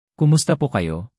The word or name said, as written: kumusta po kayo